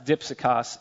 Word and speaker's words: dipsychos: The Greek word he uses there is, is the word, uh, it's pronounced "dipsychos." It looks like di psychos.